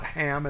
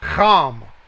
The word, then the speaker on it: Cham: It's not Ham, it's Cham. That's how it's pronounced, 2526. It's like the German ox sound. It's C-H-A-M, Cham.